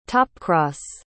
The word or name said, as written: topcross